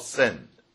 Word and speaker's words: Sin: It's called Sin. The pronunciation is Sin, not Sin.